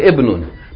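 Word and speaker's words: ibnun (ابن): then the initial Kasra is dropped in pronunciation. Meaning, instead of saying Ibnun or ismun, that kasra is actually, it has been added for pronunciation, because the word really, ibnun, really begins with the letter ba, with sukoon. And you can't begin a word with sukoon in Arabic language. So they added hamza til wasl, and in this case they put a kasra there in order for you to pronounce it.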